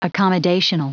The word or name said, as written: accommodational